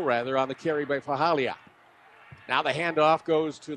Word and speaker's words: Faalii: And I tell you, if you're listening along, well, you obviously listen on one side, it is spelled F-A-A-L-I-I. But it's pronounced Fahalia. Fahalia. Gavier Fahalia. Say it a few times, it'll be smoother.